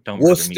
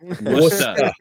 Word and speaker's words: Worcester: Don't Worcester. Worcester. – Worcester.